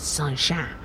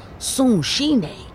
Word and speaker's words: Sunshine: See, look, most people don't know this, but it's not Sunshine. It's actually pronounced Sunshine. My grandfather was Japanese.